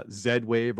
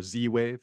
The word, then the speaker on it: Z-Wave: And then there's other protocols like Z-Wave or Z-Wave, you know, depending what country you're from, how you pronounce that.